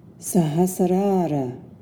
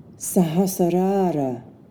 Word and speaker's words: Sahasrara: And finally, the crown chakra, or the seventh chakra, is pronounced Sahasrara, Sahasrara.